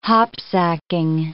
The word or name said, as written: hopsacking